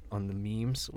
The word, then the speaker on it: meme: Is that how you pronounce that word? – Memes. – Because I'd said it. M-E-M-E. I'd seen it, but like I didn't know how to pronounce it.